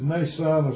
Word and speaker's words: Mnason: And I think the M there is silent, and that's the way I pronounce it, is Mnason of Cyprus.